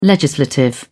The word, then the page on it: legislative: British English